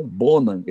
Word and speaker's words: Bonham: mas o, o, jeito certo de falar o nome do baterista do Led Zeppelin é John Bonham. Esse H não fala.